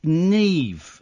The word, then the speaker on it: Niamh: Neve. – Yeah. – It's spelled N-I-A-M-H. That's just insane.